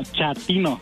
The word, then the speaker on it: chatino: Chapino. – ¿Chapino? – No, chatino. – Es como chato, ¿verdad? Chatino. – Sí, asunero, como chato, chatino.